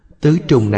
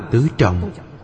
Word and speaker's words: tứ trọng: Tứ trùng này đọc là tứ trọng, ý nghĩa đều dùng được.